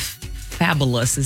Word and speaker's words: Fabolous: She reunited with Fabulous. Is that the way I say that name? – You're saying it correctly. Look at you. Fabo. F-A-B-O-L-O-U-S.